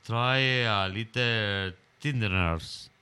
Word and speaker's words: Try a Little Tenderness: Y vamos a escuchar su Try a Little Tenderness.